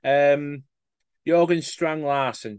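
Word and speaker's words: Jørgen Strand Larsen: I think he was interviewed by Jorgen Strand Larsen.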